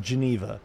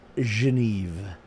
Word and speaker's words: Genève: Geneve is the Swiss pronunciation for the capital of Switzerland. In English, we call it Geneva. But the way they spell it is the traditional local way, which would be pronounced Geneve.